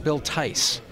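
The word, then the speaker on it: Theiss: And one day I called information and got the number for Paramount Pictures, called the studio and asked for, I pronounced it Bill Thies at the time. – Right. – And they corrected me. They said, no, it's Bill Tice. – I thought it was Thies. – Yeah, it's Tice.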